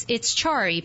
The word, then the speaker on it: Carii: Again, her name is Denise Heyman Loa, and Denise is the CEO of Chari, and it's Chari pronounced, but it's spelled C-A-R-I-I.